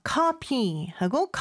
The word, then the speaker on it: copy: copy is C-O-P-Y. – 그쵸. copy하고 coffee. 발음 차이가 있습니다. P하고 F 발음 꼭 차이 네. 나게 해주시고요.